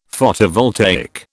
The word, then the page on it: Photovoltaic: British English